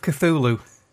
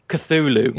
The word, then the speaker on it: Cthulhu: Call of Cthulhu. – Cthulhu.